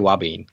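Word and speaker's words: ouabain: You mentioned something else called, I don't know if I'm going to pronounce this right, wabane? – I say wabane.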